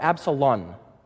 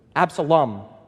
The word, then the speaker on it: Absalom: not so with Absalom. Am I saying that right? Absalom or Absalon? In English, I always get the Portuguese, Absalom with an M, Absalom. So you pronounce that M that way, Absalom.